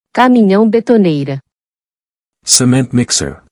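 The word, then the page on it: Caminhão betoneira: Portuguese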